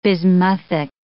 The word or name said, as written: bismuthic